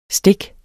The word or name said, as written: stik